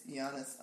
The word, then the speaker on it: Giannis: I think it's pronounced Giannis. – Is it? – It's Giannis Antetokounmpo.